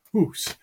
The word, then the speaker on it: hus: So H-O-U-S-E in Chaucer's time was still pronounced hus.